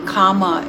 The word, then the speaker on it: kama: And remember, the word karma is actually Sanskrit, and kama is in Pali how we will pronounce it, or how we say it in Pali.